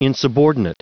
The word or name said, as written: insubordinate